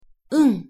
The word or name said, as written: うん